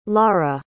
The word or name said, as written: lara